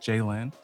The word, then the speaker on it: Jaylen: And they could not pronounce my English name, which is like, it's just Jaylen. – Jaylen. – Yeah. So they would call me like, Chelly.